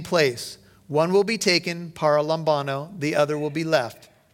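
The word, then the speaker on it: paralambano: The Greek word is para lambano. This is important. I'm going to spell it for you. Para, P-A-R-A. Lambano is L-A-M-B-A-N-O.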